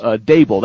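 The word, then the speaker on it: Daboll: Dable, that's how you say it, right? Brian Dable? – Yes.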